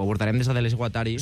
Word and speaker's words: Guattari: De Deleuze i Guattari. O Guattari. Ben pronunciat.